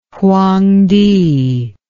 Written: Huang Ti

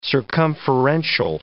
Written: circumferential

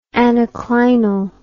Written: anaclinal

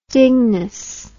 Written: dinges

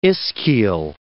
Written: ischial

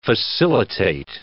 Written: facilitate

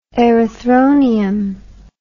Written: erythronium

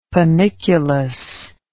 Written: panniculus

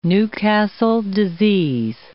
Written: Newcastle disease

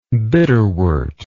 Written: bitterwort

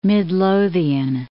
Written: Midlothian

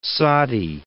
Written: Soddy

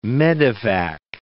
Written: medevac

